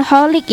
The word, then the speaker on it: Cholik: Pengunde malam dari Mang Ridwan Colik nih ya. Mang Ridwan Holik ya bacanya, C-H-O-L-I-K gitu, Holik ya.